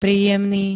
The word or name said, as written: príjemný